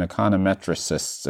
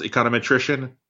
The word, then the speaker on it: econometrician: I don't think I've talked to a ton of economists and econometricists, if that's the right word. How's the transition been from econometrics to data science? – Yeah, great question. So it's pronounced econometrician.